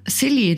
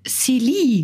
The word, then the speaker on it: Silly: Und es kommt aus der Brasserie de Silly, glaube ich, wird es ausgesprochen.